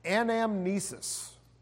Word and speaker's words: anamnesis: And the word is this anamnesis.